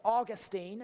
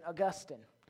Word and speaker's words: Augustine: Now, his name is pronounced Augustine, if you're from the States, or if you want to sound really intelligent, Augustine.